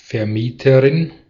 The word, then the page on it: Vermieterin: /fɛʁˈmiːtəʁɪn/